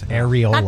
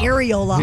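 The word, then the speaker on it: Arriola: Paul Areola? – Areola. – Yes, Areola. – Not Areola.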